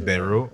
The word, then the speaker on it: Beirut: Yeah, it's Beirut.